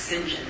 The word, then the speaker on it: St. John: St. John is pronounced St. John if you're British.